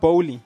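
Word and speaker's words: Boehly: How do you say his surname? – Oof. – Todd Bowley. – Yes. – That's how you say his surname. Yes. Thank you.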